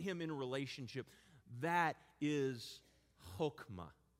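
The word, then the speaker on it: chokmah (חכמה): But wisdom in the Hebrew is the word chokmah. Chokmah. It's spelled H-O-K-M-A-H.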